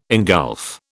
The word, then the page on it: ingulf: British English